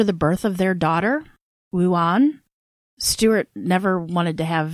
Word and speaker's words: Wuane: But yeah, it's spelled W-U-A-N-E. And I'm like, there's no way I'd be able to pronounce that. – Wuane is the normal girl. Wuane is the fancy, snooty girl. – It is Wuane.